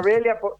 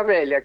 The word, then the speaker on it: Velha: A ver, espera que voy a Barra Velha, déjame anotar.